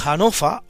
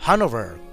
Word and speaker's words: Hanover: que introducen en Inglaterra a los Hanofa o a los Hanover, como se prefiera, pronunciado a la alemana de donde proceden o a la inglesa a la que se verán destinados.